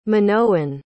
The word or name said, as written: minoan